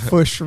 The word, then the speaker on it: Fourche: The Fush River. – Have y'all heard of the Fush River? – That's one of our major waterways here in Arkansas. – And it's spelled Fourche, you know. So it's French, you know.